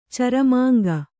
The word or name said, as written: ಚರಮಾಂಗ